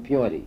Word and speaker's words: Puente: Oh, my mother bought a walnut ranch out of Peonti. – Where? – In Peonti. – How do you spell that? – P-U-E-N-T-E, La Puente. It's the bridge in Spanish.